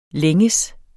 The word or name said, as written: længes